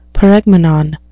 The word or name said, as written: paregmenon